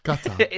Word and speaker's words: Qatar: Like if you say Qatar. – Qatar. Qatar. Like it's yeah. from like the back of your throat.